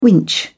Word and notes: winch: British English

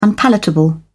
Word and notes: unpalatable: British English

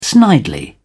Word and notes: snidely: British English

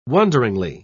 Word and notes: wonderingly: British English

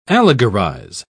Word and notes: allegorize: British English

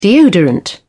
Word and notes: deodorant: British English

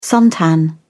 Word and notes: suntan: British English

